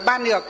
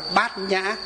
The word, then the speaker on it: Bát Nhã: Hai chữ đó rõ ràng là đọc là Ban Nhược. Nhưng chỉ có Phật giáo chúng ta mới đọc là gì? Đọc là Bát Nhã.